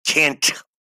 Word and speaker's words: can't: Because when you pronounce can't and you don't pronounce the T, can't, can't, can't, it's not clear for the other person.